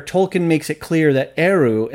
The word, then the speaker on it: Eru: If you want to hear the pronunciation of Eru, like for real, just listen to Marilyn in this follow-up interview. She's like, Eru.